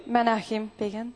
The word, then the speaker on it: Menachem Begin: Menachem Begin. – Menachem Begin.